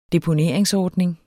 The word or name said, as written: deponeringsordning